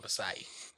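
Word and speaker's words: Açaí: I mean, at least you have somebody here that can correct you on the pronunciation of Asai. – That's true. – Yeah. – I called it Akai for the longest time.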